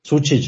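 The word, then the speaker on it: Sučić: wie spricht man ihn aus? Sucic? Sucic, ja, ja, genau.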